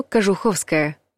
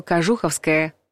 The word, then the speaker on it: Кожуховская: Метро Кожуховская. Правильный вариант. Раньше словари под влиянием вариативного произношения фиксировали два допустимых ударения у улицы и станции метро. Кожуховская и Кожуховская. Традиционным считается ударение на «у» – Кожуховская.